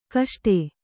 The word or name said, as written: ಗಸಟು